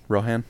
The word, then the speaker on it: Rohan: like with King Rodan. Is that how his name? Is that how it's pronounced? – Rohan.